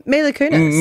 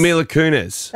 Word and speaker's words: Mila Kunis: Well, did I say the name correctly before? – Mila Kunis. – Mila Kunis. – That is Mila Kunis.